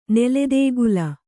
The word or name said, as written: ನೆಲೆದೇಗುಲ